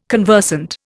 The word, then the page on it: conversant: Indian